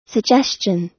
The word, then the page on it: suggestion: American English